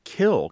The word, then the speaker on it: kiln: Kill, K-I-L-N, you say it without the N.